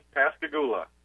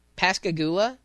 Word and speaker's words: Pascagoula: It's Pascagoula. – Pascagoula.